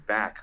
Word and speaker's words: Bakk: They're playing today as well with a player named Back, who is just. He did like it's spelled BAKK, but it's kind of funny.